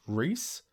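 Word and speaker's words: Rhys: Being a dwarf, Gimli is the shortest of the warriors in the Fellowship, but his actor, John, I think it's pronounced Reese, John Reese Davies, is over 6 feet tall.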